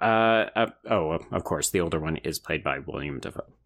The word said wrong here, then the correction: Willem: So the older one is played by Willem Dafoe.